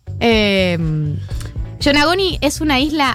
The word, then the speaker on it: Yonaguni: Bad Bunny sacó un tema, que voy a buscar el nombre porque no lo sé pronunciar bien. Yonaguni. Yonaguni se escribe Y-O-N-A-G-U-N-I. – Es una comida, ¿no?